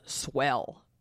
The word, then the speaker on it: Swehl: So, and the last thing I'm going to talk about with breastfeeding is a couple friends of mine started a company called Swell, and that is spelled S-W-E-H-L.